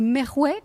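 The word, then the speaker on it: Merwah: alors je vais peut-être pas très bien les prononcer, hein, je suis désolée d'avance, le Merouet et l'Obeidi, ah, c'est bien, comme ça. C'est comme ça.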